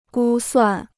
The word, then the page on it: 估算: gū suàn